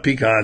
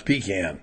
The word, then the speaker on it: pecan: It's not pecan. It's pecan. – It depends. If you're from up north, If you're from up north, it's pecan.